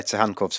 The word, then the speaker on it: Tsygankov: Am I getting it right with Viktor Sihankov? – Yeah, Sihankov's fine.